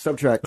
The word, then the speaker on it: SBTRKT: It's the, how do you pronounce SBTRKT? – Subtract. – Oh, subtract. – Subtract.